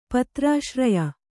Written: ಪತ್ರಾಶ್ರಯ

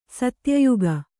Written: ಸತ್ಯಯುಗ

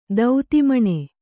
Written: ದೌತಿಮಣೆ

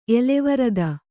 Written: ಎಲೆವರದ

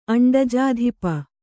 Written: ಅಂಡಜಾಧಿಪ

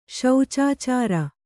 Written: ಶೌಚಾಚಾರ

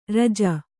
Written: ಋಜು

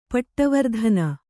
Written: ಪೀಠವರ್ಧನ

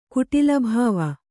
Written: ಕುಟಿಲಭಾವ

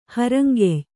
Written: ಹರಂಗೆಯ್

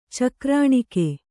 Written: ಚಕ್ರಾಣಿಕ

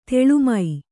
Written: ತಾಲೀಮ

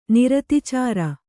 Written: ನಿರತಿಚಾರ

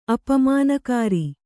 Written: ಅಪಮಾನಕಾರಿ

